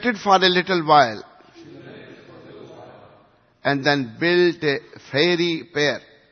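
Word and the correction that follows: fiery pyre: Excuse my, pronunciation. Fiery pair, is it right? Yeah.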